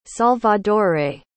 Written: salvadore

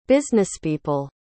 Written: businesspeople

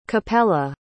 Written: cappella

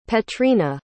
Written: petrina

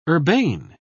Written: urbane

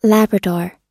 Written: labrador

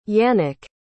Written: yannick

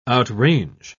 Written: outrange

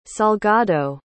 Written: salgado

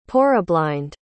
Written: poreblind

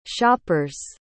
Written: shoppers

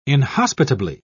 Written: inhospitably

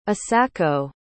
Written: Asako

